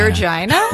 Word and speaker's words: Regina: Regina? – Regina.